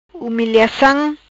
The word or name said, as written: Humiliacam